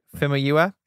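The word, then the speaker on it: Famuyiwa: Femaiwa.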